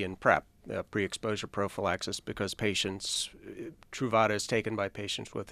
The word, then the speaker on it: Truvada: Truvada. – Truvada.